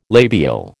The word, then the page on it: labial: American English